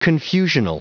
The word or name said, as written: confusional